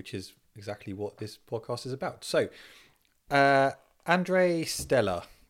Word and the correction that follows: Andrea Stella: No, say his name again. – Andrei Stella. Andrei. Andrea Stella. There we go.